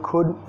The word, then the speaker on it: could: Acá el could, tengo otro video explicando muy bien cómo pronunciarla, pero vas a comer la D y el sonido no es could, could, más could. Could.